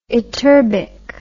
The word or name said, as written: ytterbic